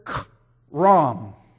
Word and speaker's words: khayram: The first one that we find, it is in the word ban, B-A-N. The word that's translated as ban or devoted in some translations, it is the word k-ram. K-ram. If you want to spell it, it's in your outline, K-H-A-Y-R-A-M.